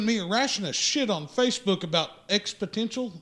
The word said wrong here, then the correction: exponential: Well, it's exponential. – X-Potential. – No. No, exponential.